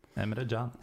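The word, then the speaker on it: Emre Can: Uh, jeg syns Emre Chan, er det sånn man uttaler det, eller dere som føler litt sånn tyrkisk? Chan? – Emre Jan.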